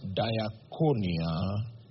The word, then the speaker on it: diakonia: If you see the pilot numbers, Greek 1249, that is the pilot number for the word translated administrations, and it is pronounced diakonia, which means service.